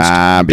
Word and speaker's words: Derby: Darby. Not Derby. Spelled Derby, pronounced Darby. Darby.